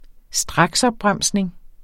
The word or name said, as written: straksopbremsning